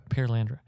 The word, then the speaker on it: Perelandra: Uh, I think it's pronounced Perelandra. Right. – Per, hmm. Perelandra. – Perelandra.